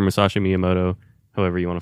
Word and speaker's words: Musashi Miyamoto: I don't think he pronounced that correctly. Try it again. – Musashi Miyamoto. – I think that's right. That sounds more right.